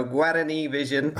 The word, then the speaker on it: Guarani: You tell me how you pronounce it. You're in Houston now and you follow Paraguay. Good evening. – Good evening, Dalton. Yeah, you were almost there on the second go. Guarani Vision is how we stress.